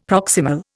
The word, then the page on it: proximal: Indian